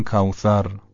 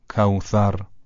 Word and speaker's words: کوثر: اگه سین رو توک زبونی تلفظ بکنیم، یعنی یه مقدار نوک زبان اون بین دندونهای بالا و پایین قرار بگیره، حرف سه سه نقطه به راحتی تلفظ میشه. مثلا کوثر به جای کوثر، نوک زبان یه مقدار که از بین دندونها بیاد بیرون تلفظ درسته. انا اعطینا کل کوثر، کوثر، به همین راحتی.